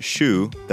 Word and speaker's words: choux: In French, shoe, that's spelled C-H-O-U-X, means cabbage, something we could all use more of.